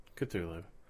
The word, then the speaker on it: Cthulhu: So I'm going on my 5th Gen Con next year, and I have yet to play Call of Cthulhu. Or Cthulhu, or whatever you pronounce Cthulhu. – It. – Cthulhu.